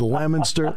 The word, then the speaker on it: Leominster: I'm the only host in the country outside of Massachusetts that actually can pronounce it correctly. – It is it is Leominster, Fitchburg, and Leominster.